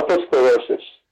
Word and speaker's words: otosclerosis: Before we get to your incident, which kind of led you to the experience with your brother, tell me about what you, otosclerosis? Am I saying that right? – Oh, yeah, otosclerosis.